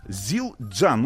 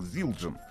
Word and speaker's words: Zildjian: что название конкретно этого бренда переводится, ну, произносится как Зилджан. – Но вот теперь, вот с этого дня, то, Зилджан.